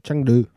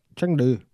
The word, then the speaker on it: Chengdu: Chengdu. – Who? – Chengdu.